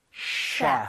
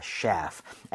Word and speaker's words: chef: 주방장, 요리사라고 얘기를 할때 셰프가 아니라 셰프. – chef입니다. chef. F는 입술 안 붙는다고 했으니까 chef 하시면 안 되겠죠? – 네.